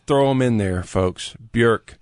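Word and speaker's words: Björk: It's not Bjork, it's Bjork.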